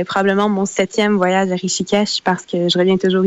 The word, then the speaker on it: Rishikesh: oui, je prononce bien Rishikesh, le nom de la ville? Oui, c'est bien ça.